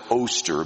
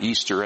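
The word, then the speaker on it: Ēostre: There is some argument that maybe some of that was borrowed from the Anglo-Saxons because they did have a goddess called Easter, or some pronounce it Oster, but most of them pronounced it Easter, and that was a celebration of, again, the resurrection.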